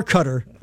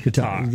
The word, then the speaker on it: Qatar: or cutter. – Oh, it's Qatar. It's not cutter. No. It's Qatar. – Qatar. Yes. – Qatar. Put the emphasis on the tar.